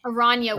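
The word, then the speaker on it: Araña: How should I be pronouncing it? – Aranya. – Aranya was Aranya. Superhero name, but no one could pronounce it. So you have to stress the N like pinata.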